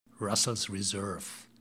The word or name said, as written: russels reserve